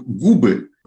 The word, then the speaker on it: губы: Тут все так, дальше вдруг губы, губам, губами о губах. Вот в форме губы, именительный, именительный падеж множества числа, у нас вдруг перескакивает ударение на основу.